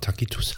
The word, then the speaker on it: Tacitus: Äh, es gibt den Schreiber Tacitus, schon mal bestimmt schon mal gehört, ich, ja, ich habe den immer Takitus ausgesprochen. also so für mich. – Ist gar nicht aber, also, falsch.